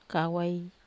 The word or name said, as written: kawai